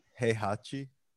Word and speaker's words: Heihachi: Heihachi.